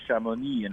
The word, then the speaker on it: Chamonix: Everyone was like, you got to go to Chamonix in France, you know, to snowboard.